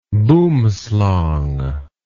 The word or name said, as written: boomslang